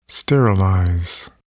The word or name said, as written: sterilize